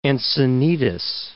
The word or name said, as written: Encinitas